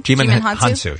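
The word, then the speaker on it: Djimon Hounsou: Jimon Hounsou. How do you say the first name? – Jimon Hounsou, yeah.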